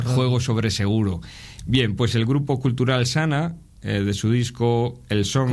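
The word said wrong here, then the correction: Xana: Se dice, ¿no se pronuncia así? – Bueno, Shana.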